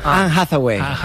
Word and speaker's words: Anne Hathaway: Anne Hathaway. – Eva, ¿está bien dicho? – Está perfectamente dicho.